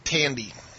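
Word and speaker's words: Thandiwe: You know, and Thandi Newton. – Tandy. Pronounces it Tandy.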